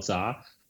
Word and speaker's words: Zha: My Twitter handle is just my name, Carl Zha. Carl spelled with a C, C-A-R-L. And my last name, Z-H-A. That's Zebra Henry Apple, Carl Zha.